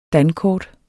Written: dankort

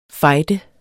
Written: fejde

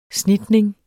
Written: snitning

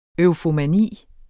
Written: EUFOMANI

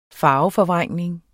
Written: farveforvrængning

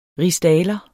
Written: rigsdaler

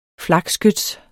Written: flakskyts

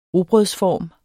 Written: rugbrødsform

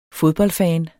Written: fodboldfan

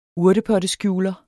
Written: urtepotteskjuler

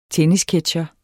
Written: tennisketsjer

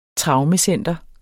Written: traumecenter

